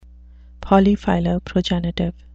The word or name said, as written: polyphiloprogenitive